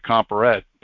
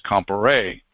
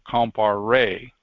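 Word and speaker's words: Compayré: Now, Bertrand Comparé, he spelt his last name as capital C-O-M-P-A-R-E-T. And a lot of people say, well, that's Comparette. Well, okay, in English it looks like Comparette, but it's a Frenchman's name, so it's Comparé, as Ella can attest to. In the French language, when it ends with an E and a T, it's pronounced A, so Comparé, got it?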